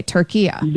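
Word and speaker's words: Türkiye: Okay, turkiya. – There you go. That's perfect. That's how it's pronounced. It's always been pronounced that in Arabic language.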